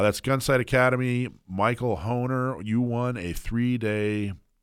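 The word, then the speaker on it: Hoener: So, all right, the winner is Michael Horner. That's H O E N E R. Michael Honer, I right. believe is how you pronounce that.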